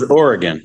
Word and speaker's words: Oregon: I thought it was Oregon, like Oregon Trail.